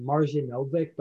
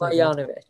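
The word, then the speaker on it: Marjanović: Boban, how do you pronounce his last name? I always call him Marjanovic, but I think it's Marjanovic. – Like, that's what I've heard like Shaq say, because I remember him in Detroit during the 16-17 season.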